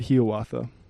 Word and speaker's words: Hiawatha: Speaking of bold, I refrained from asking last week, but is it really Hiawatha as opposed to Hiawatha? – That Longfellow himself pronounced it Hiawatha.